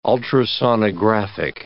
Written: ultrasonographic

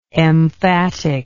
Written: emphaticalness

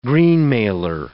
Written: greenmailer

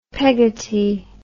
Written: Peggotty

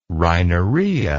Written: rhinorrhea

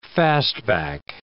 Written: fastback

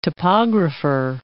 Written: topographer

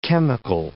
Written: chemical

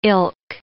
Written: ilk

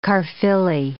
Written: Caerphilly

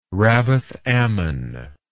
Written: rabbath ammon

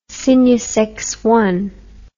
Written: cygnus loop

